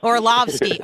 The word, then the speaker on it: Orlovsky: Am I pronouncing that correctly? – Orlovsky.